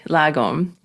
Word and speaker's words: Lagom: And I have to ask you about your latest book, Lagom.